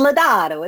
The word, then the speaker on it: Lodato: So I was telling, she asked how to pronounce my last name, and I said, it's Lodato, it's Italiano.